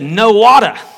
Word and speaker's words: Nowata: I didn't say no water, I said no water, and that's how it's spelled, no water, Oklahoma. And before I went to Stillwater,